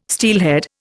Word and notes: steelhead: Indian

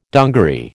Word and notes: dungaree: American English

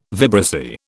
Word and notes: vibrissae: British English

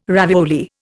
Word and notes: ravioli: Indian

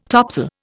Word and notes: topsail: Indian

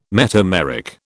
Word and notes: metameric: British English

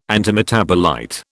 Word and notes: antimetabolite: British English

